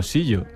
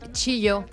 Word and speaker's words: chillo: O sea, en la portada de mi sección, pronuncias chillo como sillo. – Chillo, porque es así como catalán, la X así como gallega y catalana que tengo yo. – No, pues lo siento, me excuso en eso. – Es chillo, chillo es vale. Como CH.